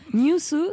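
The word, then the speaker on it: Nyūsu: N-Y-U avec un tréma, S-U, show. Vous présentiez le New Sous Show. – Exactement. – On prononce comment ? – New Sous, c'est genre la japonification, si je peux dire, de news.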